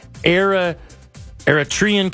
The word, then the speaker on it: Eritrean: I paid for parking downtown, and I came to enjoy quite a moment with you and the Eritrean community. The how do you say that again? – Eritrean community.